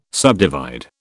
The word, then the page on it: subdivide: British English